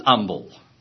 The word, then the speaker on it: humble: I was reading a letter response that a teacher had written into the Blackboard Bulletin saying that the H in humble should not be pronounced because the King James precedes it with an, and that proves that it shouldn't be pronounced.